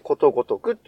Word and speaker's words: ことごとく: あの人に送り仮名でくって書いて、ことごとくって読むんですよ。